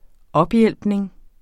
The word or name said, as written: ophjælpning